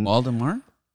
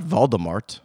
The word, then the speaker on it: Waldemar: Waldemar? – Not Voldemort.